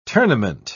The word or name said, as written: tournament